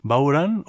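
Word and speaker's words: bodhrán: Plurinamban es el nombre de este grupo y ellas son Kathy Jordan, cantante y tañedora de Bowran, o Bodran, como se escribe y solemos decir habitualmente, ese pandero irlandés, Claudia Schwaff, que es una violinista austriaca, e Irene Buckley, que se ocupa de electrónicas, teclas y también coros.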